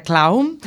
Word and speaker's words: clown: Pois, Teresa, como están escoitando, toda unha profesional e toda unha veterana, a pesar da súa mocidade, do mundo do clown, recorden que se pronuncia clown, hai algunha diferencia entre ser clown e ser payaso ou payasa?